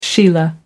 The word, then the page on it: sheila: American English